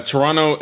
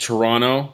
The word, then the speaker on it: Toronto: The Toronto is pronounced Toronto with one T. – Toronto. – You do not pronounce the second T.